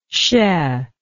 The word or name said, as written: chai